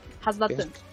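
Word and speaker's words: Radz-at-Han: Então a cidade é Radz Atan. Radz Atan. – Razdatan. – Razatan? É Razdatan, é isso? – Razdatan. – Ah. – Pelo menos foi assim que eles pronunciaram lá.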